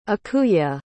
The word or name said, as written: ikuya